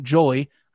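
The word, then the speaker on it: Joly: And one specific article that came up is from the Pittsburgh Post Gazette, written by a gentleman by the name of Aiden Jolly. I believe that is the pronunciation. It is like jolly, but you take one L out.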